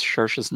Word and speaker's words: Saoirse: Yeah, because I always thought it was like Saoirse or something like that. Because it's spelled a certain way, but apparently in Irish, it's you mush them together.